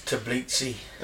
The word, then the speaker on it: Tbilisi: Vincent in Ohio, Tbilisi in Georgia. I like saying Tbilisi. – Is that how you say it, is it? – Yeah. – I think it's Tbilisi.